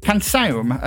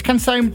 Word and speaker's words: Canção: I've been educated by Ravsig, our resident Portuguese speaker, and I'm sure I'll still get it wrong, but the very first word is actually pronounced something like Canção. It's Canção de Povo or something like that.